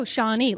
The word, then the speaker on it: Shawnee: Oh, Shawnee, like the Native American tribe. – That's right.